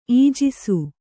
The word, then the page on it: ಈಜಿಸು: Kannada